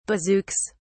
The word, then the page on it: bazookas: British English